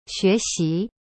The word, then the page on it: 学习: xue xi